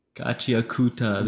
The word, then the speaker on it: Gachiakuta: Gachiakuta.